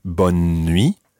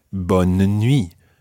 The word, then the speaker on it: bonne nuit: Sometimes it might be bonne nuit, almost with a kind of skipping N in the middle, or other times it may be bonne nuit, with almost a mini-syllable in the middle there, bonne nuit. And that just really depends where you are. Either way, it's correct. Bonne nuit.